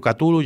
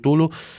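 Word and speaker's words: Cthulhu: hi ha gent que diu Catulu, hi ha gent que diu Tulu, diuen que ens faltarien tenir 23 glotis a, a la gola per poder-ho pronunciar, no?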